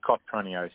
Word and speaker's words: Cotroneo: Dominic Cotronio, C-O-T. – Do you say Nertronio? – Cotronio, C-O-T. – Oh, yeah. – R-O-N-E-O, yeah.